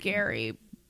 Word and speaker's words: Gerry: Well, it's spelled G-E-R-R-Y. Yeah. So I think it is pronounced Gary.